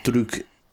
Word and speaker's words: Druk: Soul, Borat 2, and another round in the English name of it, or Druk, pronounced that properly.